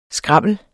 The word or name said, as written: skrammel